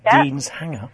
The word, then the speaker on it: Deanshanger: Deanshanger, actually pronounced Deanshanger, is a village and civil parish in South Northamptonshire, northwest of Milton Keynes.